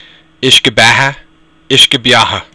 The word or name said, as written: uiscebeatha